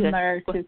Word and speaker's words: Merertu: And I'm Merertu Kitila. – Okay. All right. Great. Great to meet you. Oh, that's how you say it. Okay. I thought it was three syllables.